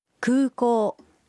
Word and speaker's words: 空港: 空港 es aeropuerto. Los sonidos u y o en 空港 se pronuncian alargados.